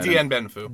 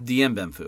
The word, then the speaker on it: Dien Bien Phu: Dien Bien Phu. – Dien Bien Phu, okay.